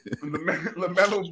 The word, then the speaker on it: LaMelo: LaMelo Ball. – LaMelo is he's a star. – Ooh, that's he's a star, and I'm gonna put LaMelo behind Garland in front of Fred.